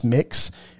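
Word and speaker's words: Mx: The educator explained that using the term mix, pronounced mix, incorporates the M-I in miss and the M-I in mister, so it's mixed.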